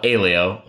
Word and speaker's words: Aleo: The founders call it Aleo, rhymes with paleo, but we even have advisors who have a different pronunciation.